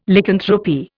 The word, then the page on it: Lycanthropy: Indian